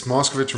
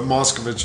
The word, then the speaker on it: Moscovic: It's Moscovic or Moscovic, whatever you want. Like, okay. It kind of goes both ways, depending on where you are, but Moscovic is the traditional...